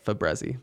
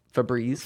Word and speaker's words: Febreze: I always say Fabrezi, but Febreze. – Febreze? – Yeah.